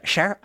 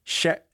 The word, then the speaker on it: cher: Cher, I can't ever say it right. – Cher, there you go. – Okay. – Well, at least that's how we say it.